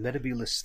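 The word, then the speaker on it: Metebelis: So Matt Smith pronounced it Metabulus, I think, and John Pertwee is Metabulus, which is more how I like to pronounce it myself.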